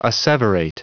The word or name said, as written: asseverate